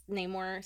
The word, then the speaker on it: Namor: And Namor. I can't say Namor. – Yes. But I say Namor because Namor sounds so annoying. And I know that's the correct pronunciation, but I can't do it, you guys. It's Namor.